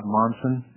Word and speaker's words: Monson: Bob Monson, M-O-N-S-O-N.